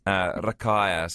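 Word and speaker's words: Rakaia: And that's Gunner Percy Stewart Collison, who came from Barhill, Rakea, if I'm saying that correctly. – Yeah, I will say in New Zealand accent so people know what you're talking about. So yes. – Rakea, so famous for the Rakea Gorge.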